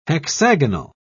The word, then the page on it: hexagonal: British English